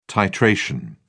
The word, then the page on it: titration: British English